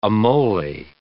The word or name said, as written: amole